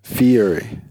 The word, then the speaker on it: Thierry: Thierry. – Like Thierry Henry. – Thierry. – The Arsenal. – Like T-H-I-E. I'm not correcting your pronunciation. – You're correct.